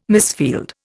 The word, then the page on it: misfield: Indian